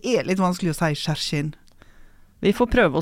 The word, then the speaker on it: Hjerkinn: Som vi får vite skal uttales Skjerkinn. Skjerkinn.